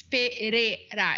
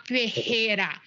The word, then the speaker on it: Pereira: Can I just interject real quick, just so, because Michelle Pereira's name, it's Pereira. It's like a hard R. It's not Pereira. You might be confusing it with Ferreira, which is actually my last name, but it's pronounced different because Ferreira has two R's and Pereira has one R.